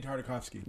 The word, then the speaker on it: Tartakovsky: Gideon Tartakovsky.